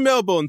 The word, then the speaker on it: Melbourne: Mate, if we were in Melbourne together, right, this is a sign of friendship. – If we were in Melbourne together...